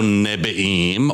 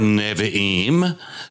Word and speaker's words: Nevi'im: So Nebi'im, or Nevi'im, depending upon your particular pronunciation, that is the word for prophets, and that's where we get the N in T-N-K.